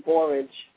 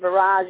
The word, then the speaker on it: borage: I was listening to how our environment really affects us, because you were saying borage, and I was like, oh, he's named barrage. Okay, so I pronounce it barrage, you pronounce it borage.